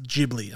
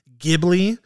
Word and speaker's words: Ghibli: Which I know people who are big Studio Ghibli fans, which by the way, it is pronounced Ghibli. I've heard a lot of people saying Ghibli.